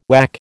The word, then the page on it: whack: American English